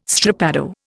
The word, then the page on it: strappado: Indian